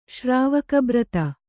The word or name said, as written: ಶ್ರಾವಕಬ್ರತ